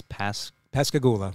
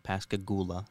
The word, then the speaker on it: Pascagoula: and the next one would be, I don't even know how to pronounce this, Pascagoula. – Yeah, Pascagoula, Mississippi, UFO incident, 1973.